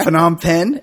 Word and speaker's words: Phnom Penh: Yeah, Phnom Penh.